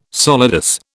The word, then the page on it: solidus: British English